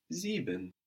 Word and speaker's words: sieben: Seven in German, the number seven is sieben. This is spelled S-I-E-B-E-N. Sieben.